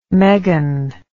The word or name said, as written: meghan